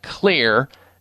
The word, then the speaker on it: Xlear: It's real simple when you're using natural nasal sprays that have an effect that's positive, like Clear Nasal Spray. X-L-E-A-R, that's how it's spelled. The X stands for xylitol, one of the components in their nasal sprays.